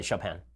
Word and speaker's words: Chopin: Dylan is asking, hey, you've talked a lot about Beethoven, but what do you think of Frederick Chopin? And I feel like I said that wrong. – You did. Chopin.